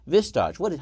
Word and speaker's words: Vistage: Vistage, how do you pronounce it? – Vistage. – let's say that.